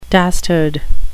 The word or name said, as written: dastard